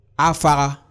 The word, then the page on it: afagha: Efik